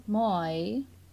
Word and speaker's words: moi: It's moi.